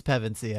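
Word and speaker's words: Pevensie: No, it's Pevensey, I believe. – Pevensey.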